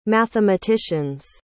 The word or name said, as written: mathematicians